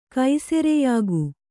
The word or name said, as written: ಕೈಸೆರೆಯಾಗು